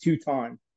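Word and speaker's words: Tooton: Yeah, it's pronounced Tuton. But some people up here, yeah, some people mispronounce it Tuton.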